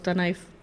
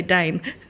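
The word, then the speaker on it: Danaë: Well, you did say Dane. In fact, you said Dane van der Kerk.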